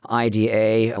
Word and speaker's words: Ida: The first one is a drama, IDA, which you can pronounce Ida as English or Ida as it would be in the Polish.